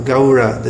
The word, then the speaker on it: Gaura: Gaura. This sound. A-U is pronounced something like the sound in how, H-O-W, how, Gaura, Gaura.